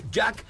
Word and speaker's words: JAC: Jack, a ver, ahí les va, apréndanselo muy bien. Se escribe JAC.